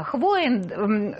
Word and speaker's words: Хвоин: С нами Ольга Хвоин, если я, надеюсь, правильно ударение ставлю в фамилии? – Да. – Хвоин, руководитель информационной службы Белорусской ассоциации журналистов.